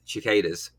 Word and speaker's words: cicadas: You just mentioned then about the sound of the cicadas, which is a much better way to pronounce it than the way I tried. – Cicadas, yes. Yeah. I don't know why I do the ch thing.